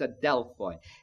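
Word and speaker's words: adelphoi (ἀδελφοί): Brethren simply means the church or the churches, a Greek word pronounced adelphoi.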